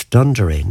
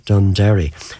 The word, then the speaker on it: Dunderry: So from now on, it's Dunderry, not Dunderry, as I would have called it over the years. The local pronunciation is always the correct one.